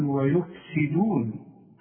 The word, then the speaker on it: ويفسدون: فالسين دي مكسورة، تقول ويفسدون، والدال مرققة دائما سواء مضمومة ولا مفتوحة ولا مكسورة.